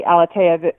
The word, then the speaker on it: Aleteia: It spells, by the way, I can never spell it right, A-L-E-T-E-I-A, Alitea.